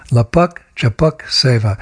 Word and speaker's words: Lapak Japak Seva: It rhymes. Lapak Japak Seva.